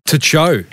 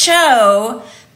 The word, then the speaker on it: Tcho: This is a chocolate company and it's spelled T-C-H-O. – To Cho. – It's pronounced Cho, but just seeing T-C-H-O, you might not know that. It looks a little bit like it could be like techno with some letters left out. But yeah, Cho.